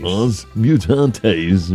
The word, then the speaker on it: Os Mutantes: Os Mutantes.